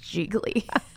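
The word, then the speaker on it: Gigli: It Jiggly? Is it Giggly? – I think we've pronounced it over the years as just Jiggly.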